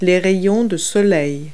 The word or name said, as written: sun rays